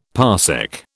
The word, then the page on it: parsec: British English